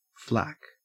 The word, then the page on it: flack: Australian English